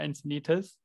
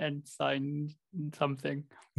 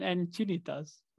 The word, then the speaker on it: Encinitas: A few episodes ago, when we were talking about Encinitas, you were pronouncing it ensign something, Mike and I just let you pronounce it that way the whole episode. I'm sure everyone was just laughing there. – Oh god, that's probably my dyslexia kicking in. Thanks for that. – That's one of the things in California, that usually people try to pronounce the place names in kind of Spanish-ish. Not perfectly Spanish, but usually the vowels go in the Spanish way.